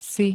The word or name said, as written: si